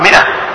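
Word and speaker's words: مائة: هذه الألف تكتب وما تلفظ، يعني تكتب هكذا ميم ألف بعدين همزة ما، لكن ما تقرأ ماء، تقرأ مئة، تقرأ مئة، تكتب الألف ولا تلفظ ولا تقرأ.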